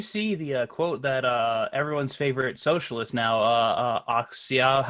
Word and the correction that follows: Ocasio-Cortez: however you say her name, Alexandria Ocasio-Cortez, yes.